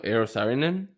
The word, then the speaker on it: Eero Saarinen: Eero Saarinen? – Yeah, thank you.